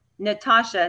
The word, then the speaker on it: Natacha: You can also find me on Instagram at Natacha, and my name is spelled N-A-T-A-C-H-A.